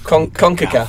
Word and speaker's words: CONCACAF: CONCACAF.